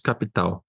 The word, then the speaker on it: Capital: Esses são números que estão nos balanços e estão no, no estudo do César, é, na, publicado no relatório Convocados Galápagos Capital. É, capital, na verdade, né? A pronúncia em inglês deixa, deixa mais bonito.